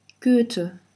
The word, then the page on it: Goethe: german